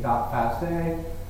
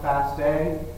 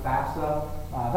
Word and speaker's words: FASTA: Oftentimes you'll see the end of the file being, or the extension being .fasta or .fa. It is pronounced FASTA. Sometimes you'll hear people pronounce it FAFSA. That's fine too, but I think within the field most of us say FASTA.